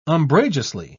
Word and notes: umbrageously: British English

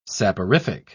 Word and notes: saporific: British English

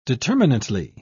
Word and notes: determinately: British English

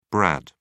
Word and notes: brad: British English

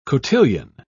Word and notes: cotillion: British English

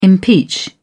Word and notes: impeach: British English

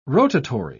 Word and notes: rotatory: British English

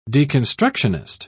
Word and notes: deconstructionist: British English

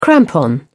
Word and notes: crampon: British English

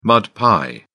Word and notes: mud pie: British English

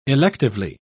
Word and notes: electively: British English

